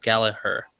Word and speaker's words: Gallagher: But why is Jack's last name being pronounced Gallagher? – That's just how he pronounced it. – Is it? Okay. – I think it's a British thing.